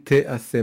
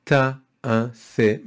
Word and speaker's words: te'ase: It doesn't say ta'ase, it says te'ase, which translates as for 6 days, work shall be done or may be done.